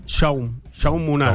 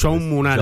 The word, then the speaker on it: Jaume Munar: ¿Cómo lo pronunciaste a Jaume? – Jaume Munar, Jaume. Porque ese catalán... Pues bueno, nosotros lo castellanizamos. – Jaume Munar, Jaume, dice él, claro, que se llama.